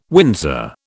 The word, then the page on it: Windsor: British English